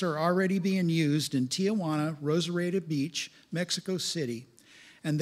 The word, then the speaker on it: Tijuana: Tijuana is pronounced Tijuana.